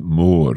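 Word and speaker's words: mòr: I was saying more, but you're saying more in a kind of more O sound. – Yes, it's quite drawn out. It's got an accent on the O, more. So we emphasise it a little bit more.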